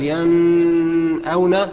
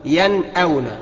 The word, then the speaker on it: ينأون: أقول لك ينأون. ها؟ ينفع؟ ينفع إن أنا أقول ينأون؟ لا. يبقى إذن إيه؟ واجب عليك إنك تظهرها. ينأون.